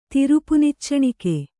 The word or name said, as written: ತಿರುಪುನಿಚ್ಚಣಿಕೆ